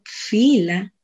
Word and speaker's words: fila: El filo, pronunciado en ladino fila,